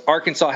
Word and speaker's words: Arkansas: Arkansas has a silent S.